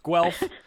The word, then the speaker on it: Guelph: Well, two listeners already who apparently are from Canada have decided to correct my pronunciation of Guelph. It's Guelph. Guelph. Guelph is in Ontario, Canada, the University of Guelph. So I am not from Canada, so I screwed that one up.